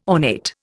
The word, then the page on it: ornate: Indian